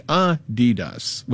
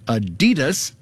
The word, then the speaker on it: Adidas: It's supposed to be Adidas, with a slight emphasis on the first syllable, not Adidas, stressing the second.